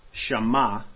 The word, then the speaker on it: Shema: So, in the Strong's Hebrews 80:85, Shema, I believe I'm actually pronouncing that right, because there's a website that will, you can click the word and they'll pronounce the word for you.